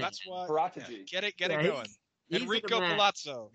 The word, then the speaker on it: Paratici: Yeah, and yes, we haven't been good at this in the past, but that's why we bought Patricio in. I don't know that Nathan, you were pronouncing that differently. I'm probably Paratici. – Incorrect. Paratici.